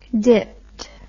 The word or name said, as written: dipped